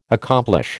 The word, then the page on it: accomplish: American English